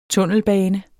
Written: tunnelbane

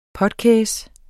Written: potkæs

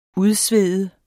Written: udsvede

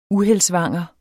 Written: uheldsvanger

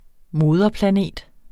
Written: moderplanet

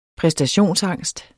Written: præstationsangst

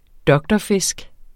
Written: doktorfisk

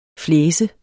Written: flæse

